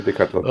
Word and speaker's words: Decathlon: Ja, nee, Decathlon.